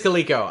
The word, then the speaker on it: Coleco: Yes, it is Coleco. I said it wrong.